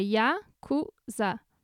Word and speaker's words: Yakuza: Que, aliás, essa é a pronúncia, não é Yakuza, como todo mundo pensa. – É que nem a gente sempre conversa, o japonês não tem sílaba tônica. Então, quando você vai ler, você lê do jeito que a sílaba é lida. Então, é Yakuza.